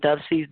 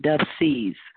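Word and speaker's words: Dubceez: And for those that might not be familiar, Dove C's does have a page, and it's spelled D-U-B like boy, C-E-E-Z, Dove C's.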